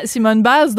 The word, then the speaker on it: Simone Biles: C'est bien Simone Biles,